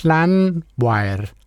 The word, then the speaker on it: Llanfair: Men altså, det starter med, at han siger Llanfair. Llanfair. Det der altså det karakteristiske valisiske dobbelt L udtales.